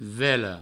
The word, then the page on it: vela: velo